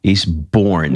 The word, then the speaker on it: Eastbourne: Eastbourne, mm-hmm.